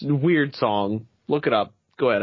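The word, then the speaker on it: Wyrd Song: It's called, I guess it's pronounced Weird Song, W-Y-R-D Song, and it's a brand new open world RPG from Bethesda, Obsidian Veterans, Bethesda alone.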